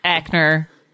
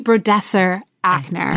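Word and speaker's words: Brodesser-Akner: You know, she had that piece that was in the New York Times magazine, that the profile that Taffy Bradner, I always fucking butcher her name. – Ackner, right? – Thank you.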